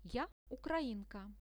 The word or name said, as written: ja ukrainka